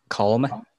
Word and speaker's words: calm: You pronounce the L. It's silent. The L is silent. – Calm.